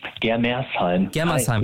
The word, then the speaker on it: Germersheim: Heißt das richtig, Germersheim? Habe ich es richtig gesagt? – Germersheim.